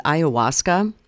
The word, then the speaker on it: ayahuasca: I don't know if I'm pronouncing this right, but ayahuasca. – Yeah.